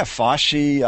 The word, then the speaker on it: FOCI: You want to make a hard C, and then you can't say it on the radio. – Yeah, Foshi.